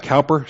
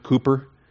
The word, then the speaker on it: Cowper: The name Cowper, it looks like Cowper, C-O-W-P-E-R. It's actually pronounced Cooper. I don't get that, but I'm assured that that's the pronunciation.